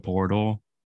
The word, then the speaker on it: Poeltl: And their only move was to acquire Jakob Poeltl from the Spurs. No R, silent R, I should say.